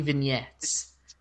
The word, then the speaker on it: vignettes: It's actually vignettes. The G is silent. It's a French word.